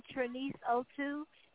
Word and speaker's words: Trenice: My Instagram is I am Trenice02, and Trenice is spelled T-R-E-N-I-C-E. My Facebook and Twitter is Trenice Music, M-U-Z-I-C.